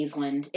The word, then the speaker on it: Aislinn: Would you say Aislinn? – I would say Aislinn. It's A-I-S-L-I-N-N Clark. We'll say Aislinn Clark.